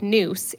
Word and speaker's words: nous: One is that because I've come across people not understanding this and then being confused, if any of the listeners want to look up noose, it's spelled N-O-U-S.